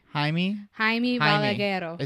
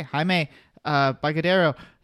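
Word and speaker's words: Jaume Balagueró: Jaume Balagueiro. – Okay. Well, we'll put it that way. – Jaime Balagueiro. – Is that how you pronounce it? – Yes. – Okay. Jaime Balagueiro and Paco Plaza.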